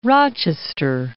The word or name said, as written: Rochester